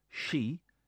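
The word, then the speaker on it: she (四): In Japan, the number 4, she, sounds like the word for death, she.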